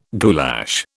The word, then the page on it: goulash: British English